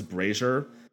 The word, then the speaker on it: Brasher: Her name is Liz Brazier. Is spelled Brasher, but is pronounced Brazier.